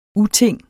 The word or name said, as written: uting